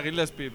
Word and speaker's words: Gillespie: No, joder, me pareció que era Gillespie, pero yo qué sé. – Ah, Gillespie, oh. – Un poco raro tú pronunciando. – Gillespie, perdona, ¿eh? – A tu rollo.